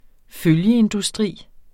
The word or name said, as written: følgeindustri